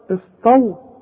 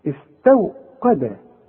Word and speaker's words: استوقد: شوف استو غلط عارف دي تنطقها زي ايه زي استوى شوف استوى استو قا ما انا بقول لك ادي كل حرف حقه في القرآن القاف مرقاه لما الواو أي مرققة والتاء احنا قلنا اتفقنا انها مرققة استوقد شوف واذا